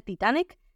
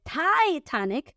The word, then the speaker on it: Titanic: Fue el final de la película Titanic, que por cierto en inglés no se dice Titanic, se dice Titanic, por lo que les expliqué de la letra I, ¿ven cómo se parece mucho? ¿Cómo se pronuncian las vocales? No es Titanic, sino Titanic.